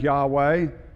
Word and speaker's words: Yahweh: There are no vowels in Hebrew. We generally pronounce that Yahweh, but we're not sure because none of us were there when they used to pronounce it.